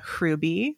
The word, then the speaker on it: Hruby: I say Amelia Hruby, like roll it together. That's more that. Czech, which is where it's from.